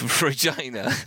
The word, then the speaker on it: Regina: We're here on our official reason for our trip to Regina. I nearly made a mistake there. Regina. – Rhymes with fun.